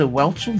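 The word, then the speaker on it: Whelchel: Lisa Welchel, did I do it right? – Yes, and I'll tell you where she's from.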